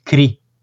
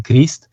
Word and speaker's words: Christ: pentru că în franceză e Jésus Christ, Cri, se pronunță, deși noi avem tendința așa la rumen să pronunțăm Crist,